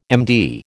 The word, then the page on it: MD: American English